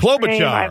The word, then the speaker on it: Klobuchar: Well, and Amy Klobuchar, is that how you pronounce Klobuchar.